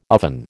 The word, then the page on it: oven: American English